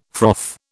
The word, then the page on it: froth: British English